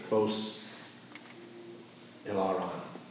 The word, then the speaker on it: Phos Hilaron: It's called the Fos Eller. Fos, I always mispronounce it. Phos Hilaron. Fos, yes, Phos Hilaron.